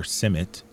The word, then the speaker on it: CIMMYT: She's the Maize Land Race Improvement Coordinator at the International Maize and Wheat Improvement Center or CIMMYT, as it's more commonly pronounced.